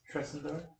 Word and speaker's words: Tresendar: T-R-E-S-E-N-D-A-R. – T-R-E-S? – T-R-E-S-E-N-D-R-A-R. I've been mispronouncing it, sorry. It's Tresendar? – Yeah, Tresendar.